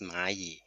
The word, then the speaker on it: Maaii: So actually, the word Mayi is coming from the Chinese word ant, which pronounced Mayi in Mandarin.